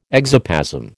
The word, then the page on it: exoplasm: American English